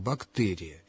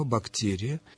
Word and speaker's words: бактерия: орфоэпический словарь русского языка под редакцией Рубена Ивановича Аванесова в качестве основной нормы рекомендует мягкое произношение согласного «бактерия» и добавляет, что допустимым является вариант с твердым произношением «бактерия». Раз допустим, значит, произношение «бактерия» ошибочным не является.